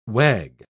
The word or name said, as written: wag